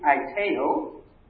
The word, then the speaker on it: aiteo: This word ask, our English word ask, comes from the Greek aiteo. If you're taking notes, it's spelled A-I-T-E-O, aiteo. A-I-T-E-O.